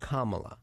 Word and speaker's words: Kamala: call her Mamala because it rhymes with Kamala.